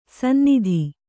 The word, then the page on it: ಸನ್ನತ: kannada